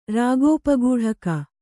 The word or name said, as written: ರಾಗೋಪಗೂಢಕ